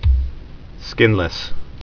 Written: skinless

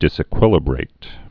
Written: disequilibrate

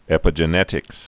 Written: epigenetics

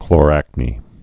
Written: chloracnes

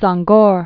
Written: Senghor

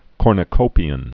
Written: cornucopian

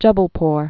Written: Jubbulpore

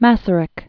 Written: Masaryk

